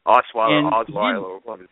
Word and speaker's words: Osweiler: and I saw Brock Osweiler. Is that how you pronounce it? – Mm-hmm. Osweiler.